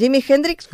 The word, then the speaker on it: Jimi Hendrix: Va, anem a parlar de, com és, a veure, en Jimi Hendrix, com es pronuncia? – Ho has fet molt bé.